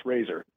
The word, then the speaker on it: RZR: Now, do you pronounce it Razor, R-Z-R? How do you pronounce it? – That's a great question. Yeah, it's pronounced Razor, R-Z. Yeah, it's spelled R-Z-R.